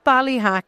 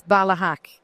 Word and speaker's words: Ballyhack: So it's not Ballyhack, it's Ballyhack. Andrew, first of all, tell me where I can find information about what you do.